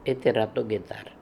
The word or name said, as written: Heteratogetar